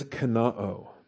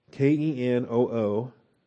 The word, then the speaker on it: kenoo: The verb is kenoo. K E N O O it's got an omicron omega ending, so the short o followed by the long o.